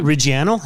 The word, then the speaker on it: Reggiano: Reggiano.